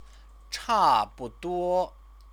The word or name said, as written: chabuduo